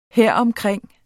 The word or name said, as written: heromkring